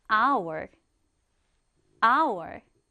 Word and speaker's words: hour: 얘는 스펠링은 H-O-U-R인데 특이하게 hour이라고 읽지 않고 이 첫 번째 글자가 소리 나지 않는 묵음이다 보니 H가 없는 것처럼 발음해 주시면 돼요. hour, hour.